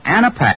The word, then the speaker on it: Anapac: So, friends, before another cold starts coming on, be sure to have your Rexall Anapac on hand. That's spelled A-N-A-P-A-C.